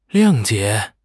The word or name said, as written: 谅解